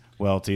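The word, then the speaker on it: Waelti: Follow me at Chris Welty. That's W-A-E-L-T-I. I'll spell it again for you. – C-H-R-I-S-W-A-E-L-T-I.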